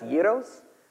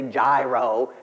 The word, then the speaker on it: gyro: And here's another thing I thought, these guys probably had the best gyros they ever had in their lives. Now, some of y'all probably say gyro. It's not pronounced like that. It's a gyro, okay?